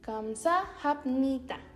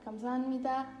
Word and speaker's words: 감사합니다: Esta es la pronunciación correcta. Kamsa hamnita. Pero los coreanos, ahora sí que para decirlo un poco más rápido o para facilitar la pronunciación, suelen decirlo de corrido y se pierde un poquito el sonido de hap. Entonces tenemos kamsa 감사합니다. Así es como tal vez lo han escuchado más. Kamsanmita, medio cantadito, ¿verdad?